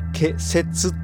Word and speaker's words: 仮説: で今も言いましたけど、普通に仮説って、仮の説って言いますけど、これ仏道読みでですね、け説って読むんですね。だから仮っていう漢字は仏教ではけと読むみたいですね。